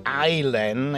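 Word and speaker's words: Eilen: I guess her name is pronounced Eileen. It's E-I-L-E-N.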